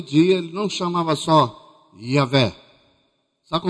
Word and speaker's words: Yahvé: A gente pronuncia Yahvé.